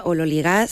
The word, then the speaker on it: ololigás: Teóricamente se pronuncia ololigás según la, bueno, pues la transcripción fonética del griego al español,